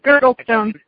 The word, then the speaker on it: Girdlestone: It's Girdlestone.